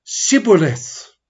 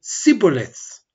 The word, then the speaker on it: Shibboleth: So as they're going back and forth, the Gileadites ask them to pronounce a word, and they meant to say Shibboleth, but because they pronounce certain things a particular way, they would say Sibboleth. So because they pronounced it, friends, the wrong way,